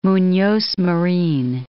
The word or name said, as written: Munoz Marin